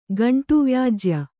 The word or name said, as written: ಗಂಟುವ್ಯಾಜ್ಯ